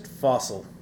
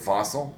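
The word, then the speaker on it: Fassel: Fossil. – Is it Fossil? – Yeah, okay. like you'd say, it's just not spelled the same.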